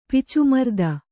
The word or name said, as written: ಪಿಚುಮರ್ದ